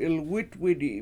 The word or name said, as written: 7ilwItwidi7